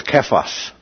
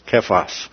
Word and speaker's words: Kephas: That's our pronunciation. Actually, the Aramaic is Kephas. Kephas. Okay, and so we anglicize it as Cephas.